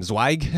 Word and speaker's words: Zweig: And Jason, I don't know how to say his last name, Zweig? – That's right, yeah.